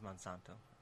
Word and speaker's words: Monsanto: Did I pronounce that right? – I think it's Monsanto.